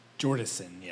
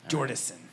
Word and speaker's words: Jordison: And I think I said that last name right. Did I say that right? – Jordison, yes. It's no N. – Jordison. It's an H at the end, right? – No. It's J-O-R-D-I-S-O-N. Jordison. – Jordison, yeah. Right.